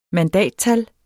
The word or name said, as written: mandattal